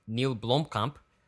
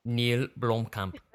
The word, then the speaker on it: Neil Blomkamp: Another filmmaker that had a good weekend was Neil Blomkamp, who made District... – Ooh, say it again, just like that, Sperling. – Neil Blomkamp.